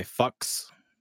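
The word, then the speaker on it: Fuchs: Um, and that's the German "fox," not the uh, American English slang. Yeah, so it's actually probably pronounced closer to the word fox, and it's that is the meaning. Um, so it's like sort of a fux. Uh, it's a very soft Wilhelm U compared to very hard yeah. fucks.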